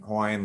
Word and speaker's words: Hawaii: And then in Hawaiian language, the W can be pronounced as a V. So you would normally say like Hawaii if you were speaking proper, like, you know, native Hawaiian language kind of thing.